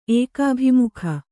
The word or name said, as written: ಏಕಾಭಿಮುಖ